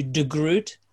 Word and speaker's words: de Groot: Well, let me first of all get the name pronunciation alternatives for people. – Yep. – So it's in the UK, we had to change the surname to de Groot.